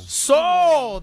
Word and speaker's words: pshaw: P-H-A. – P-S-H-A-W. Do you know Soul. – Soul. That's how you pronounce it.